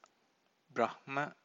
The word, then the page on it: brahma: Pali